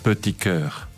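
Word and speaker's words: Petit Chœur: Petit Coeur.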